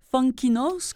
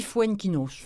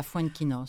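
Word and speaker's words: Foenkinos: Charlotte, donc aux éditions Gallimard, de David Fuenquinos, On dit Fuenquinos. – Fuenquinos. – Oui, on prononce ça, Fuenquinos.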